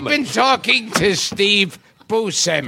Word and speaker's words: Buscemi: You said it the way I say it. – Yeah. – Buscemi.